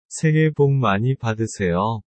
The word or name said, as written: saehae bok mani badeuseyo